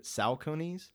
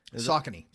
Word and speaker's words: Saucony: I've never actually heard it said, but Salconies. – Saucony.